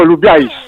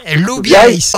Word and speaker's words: Lou Bieys: Il faut dire Lou Bieys. – Lou Bieys. – Alors qu'on l'écrit Lou Bieys. Mais en occitan, on le prononce Lou Bieys, même s'il n'y a pas de tréma sur l'orthographe que vous avez aujourd'hui partout.